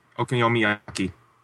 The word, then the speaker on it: okonomiyaki: Okonomiyaki.